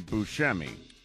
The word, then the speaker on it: Buscemi: He pronounces it Buscemi. – That's fine. And he can pronounce that around family and friends. But as an actor, he's Steve Buscemi.